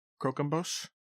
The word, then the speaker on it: Croquembouche: Croquembouche.